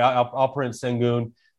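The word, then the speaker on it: Alperen Şengün: Alperen Sengun.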